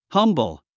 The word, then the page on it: humble: hʌ’mbl; ハムブル